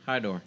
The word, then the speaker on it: Hydor: So you said Hydor. – Hydor. – Yeah. How do you spell that? – H-Y-D-O-R. – Okay. – Yeah. – Just like it sounds.